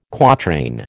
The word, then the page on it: quatrain: American English